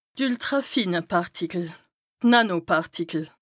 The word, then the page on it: d' ültràfine Pàrtikel / d' Nànopàrtikel: Bas Rhin